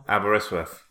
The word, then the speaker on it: Aberystwyth: So, Aberystwyth. Is that right? Did I say that right? – In Wales. – Yeah. Yeah, that's number 1. – Aberystwyth.